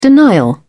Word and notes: denial: British English

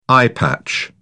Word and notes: eyepatch: British English

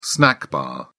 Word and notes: snack bar: British English

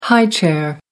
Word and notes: high chair: American English

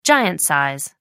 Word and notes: giant size: American English

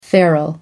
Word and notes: feral: American English

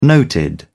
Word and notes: noted: British English

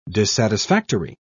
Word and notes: dissatisfactory: British English

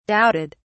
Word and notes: doubted: British English

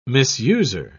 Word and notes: misuser: British English

